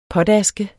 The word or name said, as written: potaske